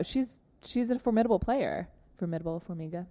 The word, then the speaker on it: Formiga: Formiga, am I saying that correctly? – Mm-hmm.